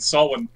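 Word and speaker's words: Samhain: How do you say it? – It's Beltana and Sawin.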